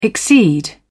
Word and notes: exceed: British English

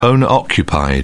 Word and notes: owner occupied: British English

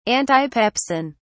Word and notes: antipepsin: British English

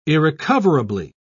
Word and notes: irrecoverably: British English

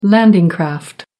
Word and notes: landing craft: American English

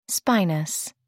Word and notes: spinous: American English

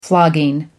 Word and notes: flogging: American English

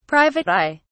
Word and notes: private eye: English; British English

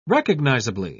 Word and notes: recognisably: British English

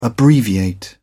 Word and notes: abbreviate: British English

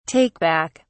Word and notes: take back: British English

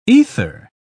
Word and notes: aether: British English

